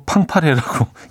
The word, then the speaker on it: 팡파레: 음, 아, 그리고 아까 그 다비치의 노래가 빵빠레가 아니고 팡파레라고 김진희 씨가 차디 빵빠레 아니에요. 빵파레 제가 빵빠레라고 소개해드렸죠. 예. 이게 가끔 그럴 때 있지 않아요? 눈은 팡파레로 읽는데 발음은 빵빠레로 나가는.